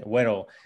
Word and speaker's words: Waro: Yeah, so it actually started, and I'm just going to correct the pronunciation, Waro. – Waro, Waro. – Yeah, it's a Spanish word, and basically it's just a nickname.